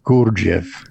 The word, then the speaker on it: Gurdjieff: Is it George Ivanovich Gurdjieff? – We can just talk about Gurdjieff.